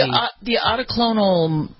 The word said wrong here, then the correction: monoclonal: how do we say it? How do you say cucumber? – Monoclonal. – Monoclonal, right. Thank you.